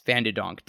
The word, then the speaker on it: Van de Donk: Let me look up to make sure with her Dutch last name that I'm pronouncing that correctly, because that's something that I do now. Yes, Van de Donk.